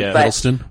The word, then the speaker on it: Hiddleston: Hiddleston.